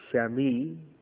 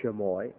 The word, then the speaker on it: chamois: Pronounced Shami or Shamoy, either one.